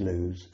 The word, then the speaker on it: Loose: Pronounced Lewes, spelt loose, looks superb, written up outside the Women's Institute.